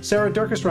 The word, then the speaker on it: Durkis: Sarah Durkis, Rhymes with Circus,